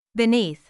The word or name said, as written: beneath